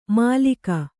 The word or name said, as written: ಮಾಲೀಕ